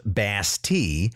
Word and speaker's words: Bastille: As a further security measure, the commander had ordered all 250 barrels of gunpowder in his arsenal to be transferred to the Bastille, which, by the way, is actually pronounced Bastille, but for once I'll make a concession to my English-speaking audience and use the pronunciation everybody already knows.